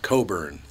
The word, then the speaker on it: Cockburn: But he comes up on stage and he says, on the microphone, he goes, actually, Norm, my name is pronounced Coburn. The C and K are silent.